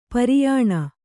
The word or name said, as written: ಪರಿಯಾಣ